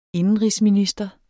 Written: indenrigsminister

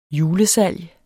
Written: julesalg

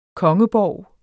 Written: kongeborg

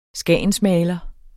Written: skagensmaler

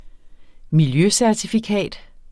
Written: miljøcertifikat

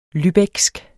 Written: lübecksk

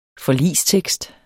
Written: forligstekst

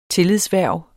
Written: tillidshverv

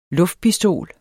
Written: luftpistol

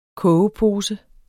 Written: kogepose

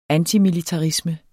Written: antimilitarisme

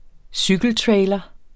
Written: cykeltrailer

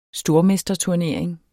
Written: stormesterturnering

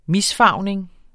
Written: misfarvning

